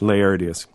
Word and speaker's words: Laertius: I mean, you remind us of the lives of the eminent philosophers by Diogenes, how do you say, Laertius? – Laertius. Mm-hmm.